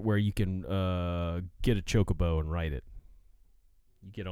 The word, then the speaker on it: Chocobo: Chocobo is the correct pronunciation, apparently. Because that's how they pronounce it on the newer games. So, Chocobo. It's Chocobo.